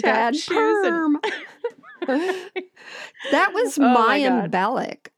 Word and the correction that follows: Bialik: or however you say her name. – Maya Bialik.